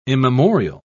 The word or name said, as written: immemorial